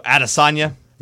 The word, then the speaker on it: Adesanya: How do you say his last name? – Adesanya.